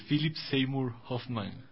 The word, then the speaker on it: Philip Seymour Hoffman: Esta peli fue estrenada en el 2008 y es protagonizada por Philip Seymour Hoffman.